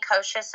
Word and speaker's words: Kocsis: Well, I mean, if you Google me, Vennie Kocsis, and my name is spelled V-E-N-N-I-E, K-O-C-S-I-S, it should bring you straight to my website.